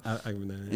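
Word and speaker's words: Abagnale: Frank Agabnail. Is that how you pronounce it? – Yeah, I can't remember. – Agabnail.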